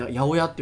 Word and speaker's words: 八百屋: 八百屋とかの 800 の屋さんお店って書いて八百屋って読みますけどあれたくさんのもの扱ってますって意味なんですよね。